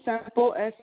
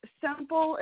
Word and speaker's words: Semple: Susan Semple, Semple. – I'm sorry, I didn't quite hear the last name. Can you repeat it again? – Oh, it's Semple. It rhymes with Temple, Semple.